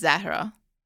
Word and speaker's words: Zahra: Well, and the way you're pronouncing it is the Arabic pronunciation. – Uh-huh. – And then the Persian pronunciation is Zahra. – Zahra. – It's like there's the H doesn't have as much emphasis.